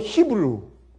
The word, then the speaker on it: 히브리: 히브리 여러분 영어로 히브루. 근데 원래 그러니까 히브리어로 옛날 아람어로 발음이 히브리예요. 그래서 옛날 그 히브리어의 발음은 우리 한국말 발음이 사실은 제대로 영어보다 훨씬 더 정확한 것입니다.